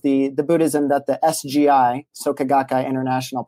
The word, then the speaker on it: Soka Gakkai: You pronounced it perfectly, by the way. It's Soka Gakkai International is the lay Buddhist organization that I'm a young men's division within and a member of.